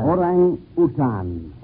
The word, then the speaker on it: orangutan: By the way, Mr. Buck, how do you pronounce that? Orangutan or orangutan? – Orangutan. – Oh, he has the correct pronunciation.